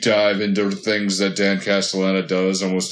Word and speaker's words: Castellaneta: Well, Dan Castellaneta is have butchered his name. Sorry. – No, you got it. – Okay. – Yeah, Dan Castellaneta is like he's a voice actor with an elastic voice.